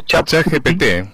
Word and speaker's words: ChatGPT: ChatGPT.